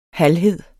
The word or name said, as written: halvhed